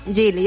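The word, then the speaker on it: Geely: Jelly. Es que claro, Gili. – Yo estoy preguntando que desde esta mañana cómo lo pronunciamos bien, Gili.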